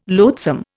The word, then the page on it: loathsome: Indian